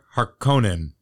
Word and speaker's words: Harkonnen: Baron Vladimir Harkonnen from Dune. – There you go. You said it right.